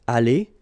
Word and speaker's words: aller: Here you've got aller, aller, even if you've got the final E, you don't pronounce it. Aller, even if you've got the S. Aller, even if you've got this ES.